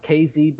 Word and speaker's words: KZ: It's pronounced KZ, but it's the same thing like crazy.